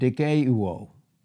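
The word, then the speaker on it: dikaiouo (δικαιόω): Justified is the Greek word dikaiouo. Dikaiouo. It's spelled D-I-K-I-O-O. One is Omicron, the short O. And the other one is omega in those two O's, and that's the long O.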